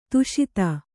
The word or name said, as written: ಧಾಷತ್ತು